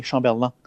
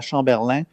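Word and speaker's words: Chamberland: Non, non, c'est Chamberlain, L-A-N-D. – OK. – L-A-N-D, Chamberlain. Puis, on prononce ça à la Chamberlain ou Chamberlain. Fait que ça,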